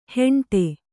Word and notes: ಹೆಂಡೆ: kannada

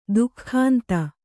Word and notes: ದಿಗಂತ: diganta

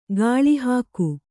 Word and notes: ಗಾಳಿಹಾಕು: gāḷi hāku

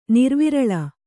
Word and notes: ನಿರ್ವಿರಳ: Kannada

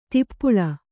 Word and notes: ದೀಪಾಳಿ: dīpāḷi

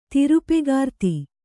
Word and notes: ತಿರುಪೆಗಾರ್ತಿ: tirupegārti

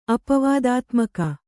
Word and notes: ಅಪವಾದಾತ್ಮಕ: apavādātmaka

